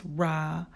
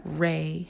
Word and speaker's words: Ra: Ra, pronounced Ra and not Re,